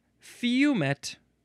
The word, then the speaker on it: fumet: Next is fume or fumet. Yeah, either one is fine. It is spelled F-U-M-E-T. So fume seems like the more proper way to pronounce it.